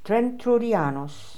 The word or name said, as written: Trentroodianos